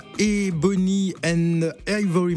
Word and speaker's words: Ebony & Ivory: On va, on s'intéresse à Ebony and Ivory Makeup. J'espère que je prononce bien. – Oui, hein, c'est ça. Parfait.